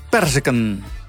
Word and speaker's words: perzik: Hoeveel kaas zitten er in het woord perziken? Hoeveel kaas zitten er in het woord perziken? – 1K. – 1K is een goed geantwoord. In perziken zit 1K omdat de klemtoon op de lettergreep per ligt.